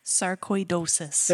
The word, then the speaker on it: sarcoidosis: Sarcoidosis.